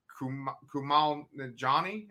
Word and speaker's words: Kumail Nanjiani: They were going to actually put Kumail Nanjiani.